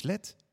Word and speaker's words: LED: Dus beste kijkers, LED is LED met kleine letters. Zo spreek je het uit en zo schrijf je het.